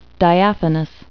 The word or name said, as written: diaphanous